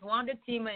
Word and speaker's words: Wanda-Tima: So Wanda-Tima is Wanda-Tima and Gilles is my married name.